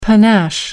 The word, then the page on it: panache: American English